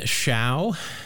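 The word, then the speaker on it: Show: All right, Eric Von Schau rhymes with now, is what I'm told by Baseball Reference.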